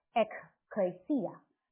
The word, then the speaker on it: ekklesia: 教会这个字呢，希腊文是把它读作 ekklesia，它是两个字组成的， ek 是出来的意思， klesia 是呼召，所以就是英文的 call 这个字，合起来就是一群被呼召的人，是住在这个世界却被呼召出来分别出来的人。